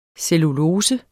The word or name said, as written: cellulose